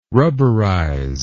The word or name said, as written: rubberize